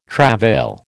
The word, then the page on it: travail: American English